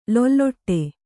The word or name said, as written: ಲಾಲಾಟ